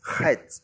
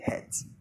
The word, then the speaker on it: Chet: So if you can't pronounce words from your gut, you can say Het. No one would arrest you for that, but it's pronounced from the gut. Chet.